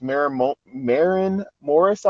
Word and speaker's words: Maren Morris: Maren Morris, I believe is how it's pronounced.